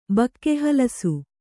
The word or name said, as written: ಬಕ್ಕೆಹಲಸು